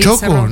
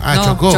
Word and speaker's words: chocó: ¿Choco? No, chocó. – Chocó, con acento en la O, ¿ya?